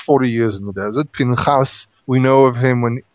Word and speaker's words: Phinehas: Phinehas. In English, they call him Phineas. Mm-hmm. The name is really Phinehas. – Phinehas. – Two syllables, Phinehas.